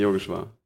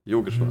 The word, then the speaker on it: Yogeshwar: Yogeshwar. Yogeshwar, mhm. ne? Vorne, mhm. vorne eher pronounced, ja.